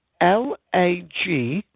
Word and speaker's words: Lagrein: It's 2010, and it's a blend of two grapes, mainly Shiraz, with Langrain. Now, I'm not sure if I'm pronouncing that word correctly. It's a grape that's new to me. It's Lagrein.